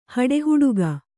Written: ಹಿಡಿಹಿಡಿಕೆ

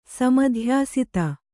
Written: ಸಮಧ್ಯಾಸಿತ

